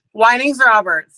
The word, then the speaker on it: Wynings Roberts: Wynings Roberts.